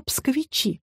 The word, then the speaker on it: псковичи: Но сами жители славного города называют себя псковичи с ударением на последний слог.